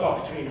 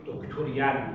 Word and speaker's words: داکترین: میگه هم میگه دین یک بخشی داره، بخش بهش به اصطلاح داکترین هاست، داکترینز به فارسی باید وقتا میگیم دکتورین، اون همون کلمه داکترینی که در عالم سیاست به کار میره و تلفظ غلطه.